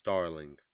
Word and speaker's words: Starling: My name is Starling, S-T-A-R-L-I. N-G. – Okay, can you pronounce it again? – Starling.